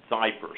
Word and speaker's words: Xypper: And it's called Zyper, and it's spelled X-Y-P-P-E-R, Zyper.